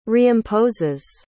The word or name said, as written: reimposes